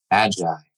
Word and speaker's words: Magai: His most recent venture, which you need to know about, is called Magai. Did I say that right? – Magai. – Yes!